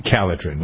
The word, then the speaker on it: Calotren: And you don't have to go on a crash diet with Kellitrin. By the way, it's spelled C-A-L-O-T-R-E-N.